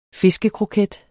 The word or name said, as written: fiskekroket